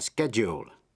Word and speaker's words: schedule: Và schedule, schedule là thời biểu. Với bốn chữ, procedure, graduate, pendulum, schedule, trong đó âm D phát âm là J.